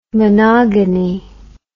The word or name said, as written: monogony